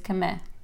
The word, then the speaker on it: Kemê: Yes, Kemê. Yes, but I'm used to be Kim, Kem, Kem, Kemê. Anything goes.